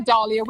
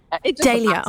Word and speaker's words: Dahlia: I had a, oh no, I always say this wrong, Dahlia. I've said it right, haven't I? – Yes. You can say Dahlia, we say Dahlia.